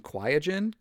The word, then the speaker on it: Qiagen: If you have any of the other ones, and that includes from I believe they pronounce it Qiagen, Qiagen, Q-I-A-G-E-N, right?